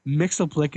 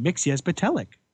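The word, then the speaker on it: Mxyzptlk: Oh, sorry. Mixoplick is pronounced Mixias Patelic.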